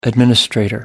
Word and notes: administrator: American English

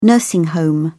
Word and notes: nursing home: British English

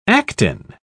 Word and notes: actin: British English

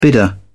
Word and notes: bidder: British English

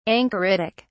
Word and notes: anchoritic: British English

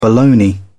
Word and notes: boloney: British English

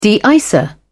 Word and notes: de icer: British English

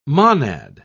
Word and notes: monad: British English

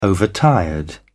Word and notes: overtired: British English

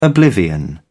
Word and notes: oblivion: British English

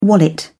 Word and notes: wallet: British English

